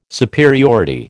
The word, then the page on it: superiority: American English